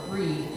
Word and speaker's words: Brigid: February 1st is celebrated in the Celtic tradition as Imol, and the central figure of this pastoral holiday is the goddess Brigid, or Breed in the Irish pronunciation.